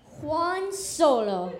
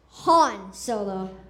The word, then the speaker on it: Han Solo: So I dreamed of becoming Juan Solo. It wasn't until I was cast in Obi-Wan Kenobi that I realized it's pronounced Han Solo.